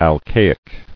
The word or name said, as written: Alcaic